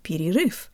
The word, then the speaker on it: перерыв: This is very close to the first two syllables in the word перерыв.